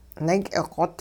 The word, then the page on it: naak'iqot: hupa